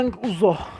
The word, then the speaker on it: Young Uzo: Young Ozo diye mi okunuyor? – Young Uzo diyebiliriz. Young Uzo da olur.